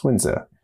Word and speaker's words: Sunzi: I had begun the interview asking Professor Lorsch what the correct pronunciation of Sun Tzu is, and that is Xunzi.